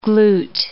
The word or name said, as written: glute